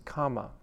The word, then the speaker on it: khama: The Pali word for forgiveness is khama, which sometimes is, it's not the kamma of karma, K A M M A, it's spelled K H A M A, and it also means the earth.